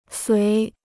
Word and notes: 随: suí

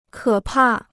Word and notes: 可怕: kě pà

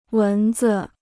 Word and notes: 蚊子: wén zi

